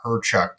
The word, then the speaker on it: Hrechuk: So it's spelled H-R-E-C-H-U-K, and we just say Herchuk to keep it simple.